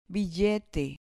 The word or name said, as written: billete